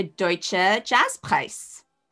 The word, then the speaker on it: Deutscher Jazzpreis: You won Best Debut Album of the Year by the Deutsche Jazzpreis. How's my pronunciation? – That's perfect.